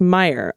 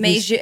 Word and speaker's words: Meijer: Rep Meijer's name is pronounced Meyer. Oh, did oh did Dana say it? Okay. Meijer is pronounced Meyer. Like if Major, it's not Major.